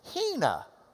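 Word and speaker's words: Hena (ἵνα): This has got a reverse comma in front, like an open mouth that goes, because it's the H sound. All right? So you would pronounce this Hena.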